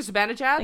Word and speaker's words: Zibanejad: Mika Zibanejad? – Thank you.